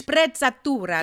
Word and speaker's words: Sprezzatura: Sprez, Sprezzatura, ist das richtig? – Sprezzatura, genau. das ist ein italienisches Wort, das ja.